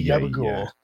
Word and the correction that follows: gabagool: Tony, you didn't say it right, Andy. – Gabagool. – Tony Gabagool.